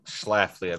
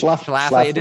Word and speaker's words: Schlafly: She talks about Phyllis Schlafly. Phyllis Schlafly was like a notable Roman Catholic, popular, you know, female conservative. – Schlafly, I believe. – Schlafly.